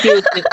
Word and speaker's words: 히읗: 단점인 듯 단점 아닌 단점 같은 단점들이라 히응 히응 히읗 이거 제가 자, 정확하게 말씀드릴게요. 히읗 히읗이라고 발음해야 돼요. 밑에 맞춤이 디귿입니다.